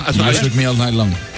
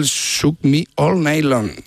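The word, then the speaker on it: You Shook Me All Night Long: Lo has dicho en francés. – O sea, has cambiado cuatro palabras de sitio. – Has engolado tanto que se te ha pasado de idioma. – Qué grande, tío. Qué grande. – Me cuesta el Shook Me All Night Long. – Ahí mucho mejor. Muy bien.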